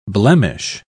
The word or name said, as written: blemish